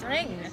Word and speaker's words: Ringu: That's how you say it in Japanese, ring. – Yeah. – Ringu. – Yeah. – Yeah. You don't say the U in Japanese.